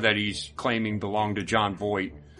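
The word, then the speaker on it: Jon Voight: Am I saying his name right? – I don't know. I don't know who you're talking about. – John Voight. – Oh, John Voight.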